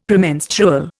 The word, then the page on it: Premenstrual: Indian